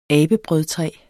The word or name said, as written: abebrødtræ